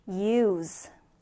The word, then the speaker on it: use: 자, 다시 동사는 use. 동사는 use, 명사는 use. 그쵸? Z와 S 발음 여러분 잘 구분해서 연습해 주셔야 됩니다.